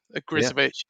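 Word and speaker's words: Ogrizovic: And Agrivis, I can't ever say his name. I can't ever say Agrizovic. Agrizovic. Yeah, in goal.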